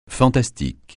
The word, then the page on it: fantastique: francais